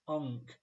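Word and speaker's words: Ang: I just wanted to clarify that the Singaporean surname is pronounced as Ung, like saying hunk, but dropping the H.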